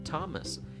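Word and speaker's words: Thomas: تامس این از اون موارد استثنا هستش در زبان انگلیسی با اینکه با تی اچ می نویسن ولی ث نمیگن نمیگن ثامس میگن تامس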